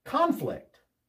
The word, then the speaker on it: conflict: First, some words change from a noun to a verb depending on stress. For example, conflict. Conflict. The first syllable is stressed. Conflict is a noun. When two people don't agree on something, they have a conflict with each other. Conflict. Conflict. The accent on the second syllable, that's the verb.